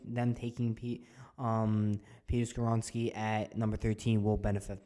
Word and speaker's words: Skoronski: So number thirteen, we have the Jets taking Peter Skoronski did I pronounce that wrong? No, you got it. Peter Skronsky, yeah.